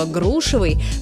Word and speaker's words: грушевый: Но вернемся к грушам и запомним, что ударение во всех формах прилагательного «грушевый» – падает на первый слог, как в слове «груша».